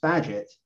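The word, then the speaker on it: Bagehot: Even these countries that are doing what I found out what Walter Baghdat, although it's actually pronounced Badgett, would recommend, and being hawkish on monetary policy, they're still being punished.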